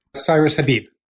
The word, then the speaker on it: Cyrus Habib: Cyrus Habib.